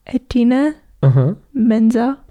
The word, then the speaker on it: Idina Menzel: Idina Menzel? – There you go.